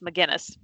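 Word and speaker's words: Megennis: I was pronouncing it Meningus, and it's McGinnis.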